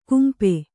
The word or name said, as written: ಕಂಪ